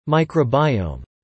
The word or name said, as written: microbiome